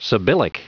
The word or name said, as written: sibyllic